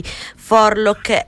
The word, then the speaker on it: Forelock: Ecco, abbiamo appena ascoltato To The Foundation, appunto, dei Forlock e Aravak. Detto bene? – Beh, direi di sì.